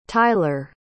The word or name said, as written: tyler